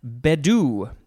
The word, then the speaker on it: bedu: Next, we have bedu. I think the emphasis is on the first syllable. B-E-D-U.